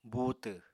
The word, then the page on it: buutw: apatani